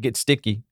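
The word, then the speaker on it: Gesicki: I mean, Mike gets sticky. That's how you say it, right? – Yeah.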